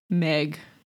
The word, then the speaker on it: Maig: Meg. It's spelled M-A-I-G.